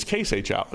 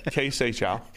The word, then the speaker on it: Kai Seychow: It was K. – Oh, it's K. It's K Seychow. No, yeah, it's K. It's got to rhyme with say. K Seychow.